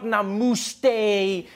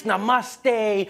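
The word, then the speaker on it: namaste (नमस्ते): People were coming on like, dude, it's not namuste, it's namaste.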